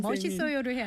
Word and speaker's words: 멋있어요: 보니까 예, 선생님 멋있어요를 해야 되는데 예. – 멋있어요. 맛있어요.